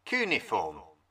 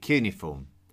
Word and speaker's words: cuneiform: Cuneiform. I call it cuneiform, but you will also hear it pronounced as cuneiform.